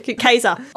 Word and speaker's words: Caesar: This is why we change pronounce and why Caesar is a soft C and not with a hard C, which it should be. – I love it. Anyway, Caesar.